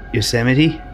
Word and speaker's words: Yosemite: Do you know the little guy with the guns in Looney Tunes? – Yeah. – What's his name? – I have no idea. – Yosemite Sam.